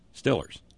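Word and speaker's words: Steelers: That's how you pronounce it in DeBerg. In Western PA, the vowel sound, long E, as in steel, is replaced by the short I sound of I, as in still, Stillers.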